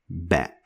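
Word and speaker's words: Ba (ب): Ba is pronounced like the B sound in English, like when you use it in the word bat. So bat, same pronunciation as ba.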